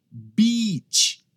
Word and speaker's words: beach: Beach é um i bem longo, tá? Até chego até sorrir. Beach. Eu estou pronunciando a long vowel sound, um som longo.